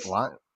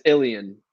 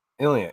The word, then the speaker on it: Ilion: But how do you pronounce the name of the town you're from? Is it Lyon? It's Ilion. – Ilion. It is Ilion.